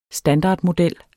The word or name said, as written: standardmodel